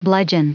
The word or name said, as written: bludgeon